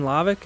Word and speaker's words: Lavik: My name is Jadon Lavik, and it's spelled L-A-V-I-K.